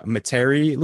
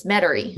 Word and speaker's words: Metairie: So you mentioned there, you are currently music director at St. Catherine of Siena in Metairie, Louisiana. Did I say that right? – It's Metairie.